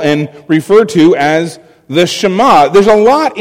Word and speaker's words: Shema: Now, it's a Hebrew word, Shema, um, and in case you're wondering how it is spelled, it is in English, the transliteration of the Hebrew is S H E M A, Shema.